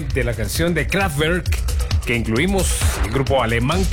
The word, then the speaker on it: Kraftwerk: Mire, le felicito porque está pronunciando muy bien el nombre de Kraftwerk. – Así es. – Sí, como lo está haciendo como alemán nativo.